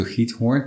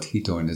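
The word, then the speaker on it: Giethoorn: Maybe we go back to Githorn, because people who don't know what Githorn is all about, and it has that difficult G in the Dutch language in it, right?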